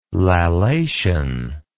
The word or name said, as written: lallation